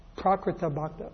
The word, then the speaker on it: Prakrita Bhakta: The Kanishta Adhikari, he's also known as the Prakrita Bhakta. Prakrita Bhakta is how it's pronounced.